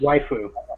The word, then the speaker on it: waifu: your weifu, or whatever the hell you pronounce it as. – Waifu.